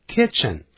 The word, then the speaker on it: kitchen: 第十五课 Mrs. Lee's Kitchen 李太太的厨房 Lesson 15 这个阿拉伯数字念成 15 F-I-F-T-E-E-N F-I-F-T-E-E-N 15 而 Mrs. Lee's Kitchen 这个厨房呢，kitchen 可不要念成 kitchen，kitchen 发 it 的音。